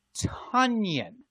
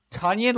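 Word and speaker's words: Tonyan: So it's Robert Tunyon. – Like Funyun. – Tunyon, like, oh my God.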